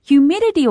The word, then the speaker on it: humidity: Temperature, 气温 Humidity, 是湿度 Humidity, 我们要注意一下它的重读音节是在第二个音节上 Humidity,